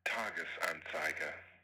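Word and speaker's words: Tages-Anzeiger: And he says the name of this Swiss newspaper is pronounced Tagesanzeiger. Tagesanzeiger? Tagesanzeiger.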